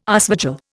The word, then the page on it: aasvogel: Indian